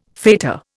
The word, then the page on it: fetor: Indian